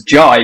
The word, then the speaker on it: Jai: Is because the J is actually it's supposed to be pronounced as Jai because I'm part Indian.